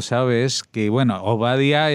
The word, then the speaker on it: Obadiah: Y ese nombre es el nombre que en hebreo se pronuncia Obadiah,